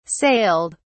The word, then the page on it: sailed: British English